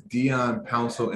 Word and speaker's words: Dion Pouncil: So my name is Dion Pouncil, and on all my social media platforms, it's spelled the exact same way, D-I-O-N-P-O-U-N-C-I-L.